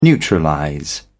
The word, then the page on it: neutralize: British English